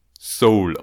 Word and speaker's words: Seoul: Und ich möchte darauf hinweisen, dass die südkoreanische Hauptstadt, die ich immer Seoul ausspreche, nämlich Seoul ausgesprochen wird, so wie die, so wie das englische Wort für Seele.